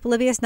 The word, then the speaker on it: Polybius: And also, is it the Polybius Conspiracy? That's how it's pronounced? Polybius, not Polybius, as my son says. It's Polybius. – I think it's Polybius. That wasn't what I was expecting. Yeah. That's how they pronounced it.